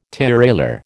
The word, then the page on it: tirailleur: American English